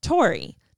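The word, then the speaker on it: Taury: Oh, Tori.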